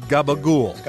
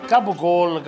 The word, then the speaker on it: gabagool: gabagool. – Gabagool. Over here. – Gabba what? It turns out it's what those of us without syllables at the end of our last name might read as capicola.